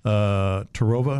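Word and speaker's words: Terrova: They have the 12-volt, 24-volt, 36-volt Minn Kota Tarova. Am I pronouncing that right? I never pronounce that right. I think Tarova's right.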